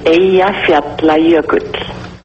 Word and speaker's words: Eyjafjallajökull: The pronunciation is Eyjafjallajökull.